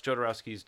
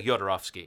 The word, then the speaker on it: Jodorowsky: I, I remember looking up oh, how to pronounce it when we talked it's about Hispanic. It. It's like Yodorovsky.